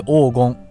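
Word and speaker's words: 黄芩: 金っていう成分の話なんですけど黄金っていう生薬があるんです。黄金ってねまあ皆さんご存知ないと思いますけど黄色って書いてそれに草冠に今って書くんですよね。おそれで黄金黄金って読みますけど、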